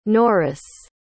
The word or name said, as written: Norris